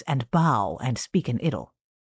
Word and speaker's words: idyll: Now, a note on the pronunciation of that word, I-D-Y-L-L. In England, they pronounce that idyll, and generally in America, they pronounce it idyll.